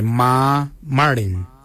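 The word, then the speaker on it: marrmardiny: Ma kuju is spelled M-A-R-R, then it's G-O-O-D-J-A-L. For 8 we say ma marin. Ma marin is number 8. Ma is spelled M-A-R-R, then it's M-A-R-R-D-I-N-Y.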